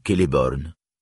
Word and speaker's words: Celeborn: Il en va de même au milieu d'un mot où « e » se prononce toujours, comme dans « Celeborn »,« Menegroth ».